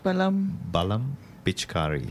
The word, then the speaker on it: Balam Pichkari: How do I pronounce the title again? – Balam Pichkari.